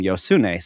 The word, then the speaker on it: Josune: and several Spanish people correcting them saying it's Josune. That's how you pronounce the J in Spanish. But I've seen interviews with her where she pronounces her name Yosune. So that's what I'm going with.